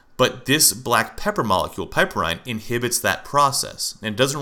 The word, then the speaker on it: piperine: And piperine, I hope I'm pronouncing that right. It's P-I-P-E-R-I-N-E. Piperine, I believe that's what it is.